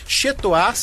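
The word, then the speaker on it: Chez Toi: Chetoar se escreve C-H-E-Z e Toar se escreve T-O-I.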